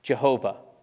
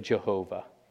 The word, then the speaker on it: Jehovah: And so this is where we get the name that sometimes appears in English translations, Jehovah. Because that first Y in German is pronounced like a J, the W is pronounced like a V, you've got an H on either side of that, and in Hebrew you're only getting the consonants.